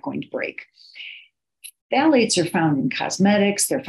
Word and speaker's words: phthalates: The pH is silent, so this is phthalates.